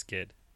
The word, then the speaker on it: Ged: Yeah, Ged is correct. It's not pronounced Jed, it's Ged.